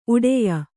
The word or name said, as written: ಉಡೆಯ